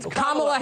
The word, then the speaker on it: Kamala: You're not allowed to criticize Kamala Harris or Kamala Harris or whatever. – Kamala Harris.